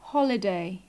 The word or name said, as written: holiday